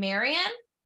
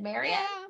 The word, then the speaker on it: Marian: That's how you say her name? – Marian? Yeah. Okay. It's like Robin Hood, Marian? – Yeah.